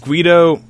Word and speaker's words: Gweedo: Guido in 3D, I believe, and it took me like half the show to figure out, is Guido, because it's spelled G-W-E-E-D-O, But like we say Guido often on the show, right?